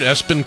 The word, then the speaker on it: ESPN: And it doesn't sound too weird, Espen Cole, but it's spelled ESPN, Cole.